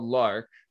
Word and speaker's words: Learke: So the the name of the so the the shop is called Lark, right? Mm-hmm. – And yes. – it's spelled L E A R K E.